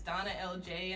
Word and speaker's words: DawnaLJ: So my personal Instagram is Donna LJ, and it's not spelled like my real name.